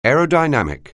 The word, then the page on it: aerodynamic: anglais